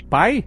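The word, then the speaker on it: pie: Pai? – Exatamente. Você pronunciou sem o aspiração que a gente tem no P em inglês. Então em inglês, na verdade, não é pai, é pai.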